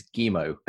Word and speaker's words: Guillemot: I know, because Guillemot, I always used to call him Yves Guillemot. It's Guillemot.